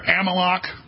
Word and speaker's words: Amalek: But you heard Netanyahu declare Amalek. That's how you pronounce it in the Old Testament.